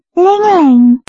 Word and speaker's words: Ling Ling: I'm Ling Ling. – Sorry? – I'm Ling Ling. – Can you spell it, please? – Yes. L-I-N-G-L-I-N-G.